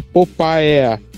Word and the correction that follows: Papaya: Popaia, eu não. não papaya. – papaya.